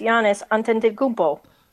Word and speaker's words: Giannis Antetokounmpo: I'm going to guess Giannis Antetokounmpo.